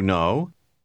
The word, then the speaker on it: know: Ne prononcez pas la lettre K du dernier mot. – Do you know?